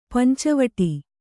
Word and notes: ಪಂಚವಟಿ: panca vaṭi

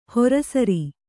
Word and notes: ಹೊರಸರಿ: Kannada

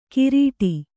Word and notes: ಕಿರೀಟಿ: kirīṭi

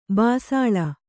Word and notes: ಬಿಸಿಲ್: bisil; Kannada

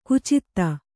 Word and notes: ಖಚಿತ: khacita; Kannada

